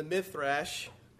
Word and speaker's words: Midrash: Two other authorities we can consult to confirm the accuracy of the Masoretic manuscripts, and that is the Talmud and the Midrash.